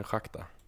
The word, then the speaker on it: Rakta: eh, som stav, de, de stavas Rakta, men det uttalas tydligen Shakhtar.